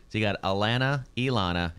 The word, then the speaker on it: Elana: E-L-E-N-A. – Okay. My wife, her name is Elana. E-L-A-N-A.